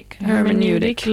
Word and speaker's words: hermeneutic: Hermeneutic labor.